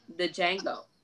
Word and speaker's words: Django: The Django. The D is silent, motherfucker.